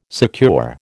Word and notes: secure: American English

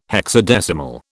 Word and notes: hexadecimal: British English

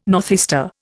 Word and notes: northeaster: Indian

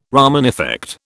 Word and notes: Raman effect: British English

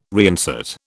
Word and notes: reinsert: British English

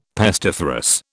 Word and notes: pestiferous: British English

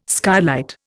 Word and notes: skylight: Indian

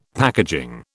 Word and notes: packaging: British English

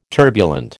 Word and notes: turbulent: American English

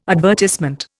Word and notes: advertisement: Indian

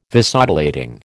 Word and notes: Vasodilating: American English

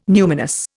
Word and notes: numinous: Indian

